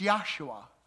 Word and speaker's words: Yahshua: By the way, you know Joshua, they didn't pronounce the J. If you went back in this time and said, hey, where's Joshua? They wouldn't even know who you're talking about. It's Yahshua or Yahshua.